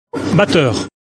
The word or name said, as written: Batteur